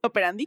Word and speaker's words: operandi: Mismo, mismo modus operando. operandi, ¿cómo se dice? Operandi, ajá.